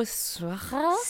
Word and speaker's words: Siwa: So pronounce it as it's spelled. – Siwa. – I just thought it was fancy. I thought Siwa. It was like Squaw.